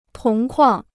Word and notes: 铜矿: tóng kuàng

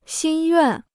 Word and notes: 心愿: xīn yuàn

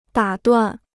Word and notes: 打断: dǎ duàn

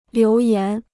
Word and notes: 留言: liú yán

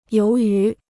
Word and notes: 由于: yóu yú

